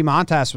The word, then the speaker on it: Montas: How do you say his last name? – Montas, yeah. Sure.